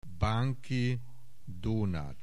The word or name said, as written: Banki Donat